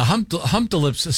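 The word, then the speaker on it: Humptulips: I wonder if they pronounce, maybe it's pronounced Hump Tulips. – Boy, Hump-to-lips is almost more worse. – Offensive. – Yeah. – I like it. Yeah. But yeah, it's Hump-to-lips. Yeah, Hump-to-lips sounds like a purple giraffe on a kid's TV show.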